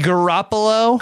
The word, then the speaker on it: Garoppolo: Jimmy Garoppolo? – Garoppolo.